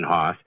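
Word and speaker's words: Hauss: Now, the speech was pronouncing it house, but it's H-A-U-S-S. So that's definitely Len Haas.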